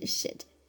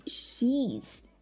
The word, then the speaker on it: sheets: 这里面的 e 呢是一个长元音，大家千万不要发成短元音，那就变成 shit，是另外一个意思了，骂人的话哈，来注意一下发音，长元音 e sheets sheets 床单。